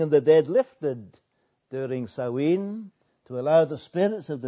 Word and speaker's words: Samhain: It originated some 2,000 years ago when the ancient Druids held a festival, spelt S-A-M-H-A-I-N, but pronounced Samhain.